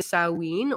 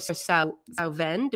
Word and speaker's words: Samhain: It's pronounced either Samhain or Samhain, depending on which sort of Celtic set you're from. And, and then there's a lot of other other ways too that are spoken.